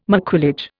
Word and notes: maquillage: Indian

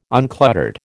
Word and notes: uncluttered: American English

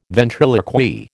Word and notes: ventriloquy: American English

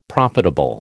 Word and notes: profitable: American English

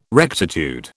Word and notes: rectitude: British English

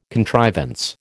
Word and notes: contrivance: American English

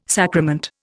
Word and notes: sacrament: Indian

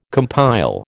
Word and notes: compile: American English

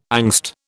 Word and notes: angst: British English

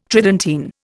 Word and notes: Tridentine: Indian

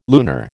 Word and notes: lunar: American English

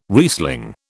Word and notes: Riesling: British English

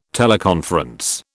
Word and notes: teleconference: British English

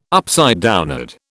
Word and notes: upside down: British English

